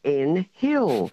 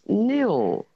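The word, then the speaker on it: Nhill: And we often get called in Hill. Well, it's not. The the H is silent and it's N-H-I-L-L, of course, and it's pronounced Nil.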